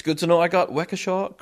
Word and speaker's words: Waukesha: He said, you did pronounce Waukesha correctly.